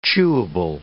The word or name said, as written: chewable